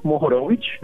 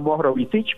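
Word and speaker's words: Mohorovičić: el señor Mohorovic, ah, Mohorovic, perdón, de hecho es tan difícil de pronunciar que le dicen mojo,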